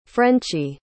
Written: frenchy

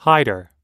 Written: hider

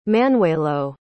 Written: Manuelo

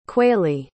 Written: quaily

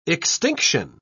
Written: extinction

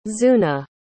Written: zuna

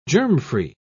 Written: germfree

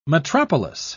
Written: metropolis

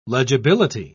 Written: legibility

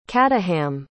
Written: caddaham

